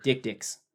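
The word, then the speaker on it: dik-dik: And I send her pictures of dick dicks.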